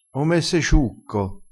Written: ome

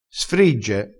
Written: sfrigge

